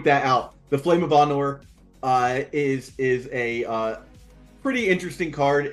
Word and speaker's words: Anor: Anor.